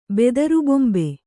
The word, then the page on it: ಬೆದರುಬೊಂಬೆ: Kannada